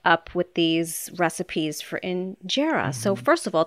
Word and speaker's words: injera: What I've been trying to add to my repertoire is injera.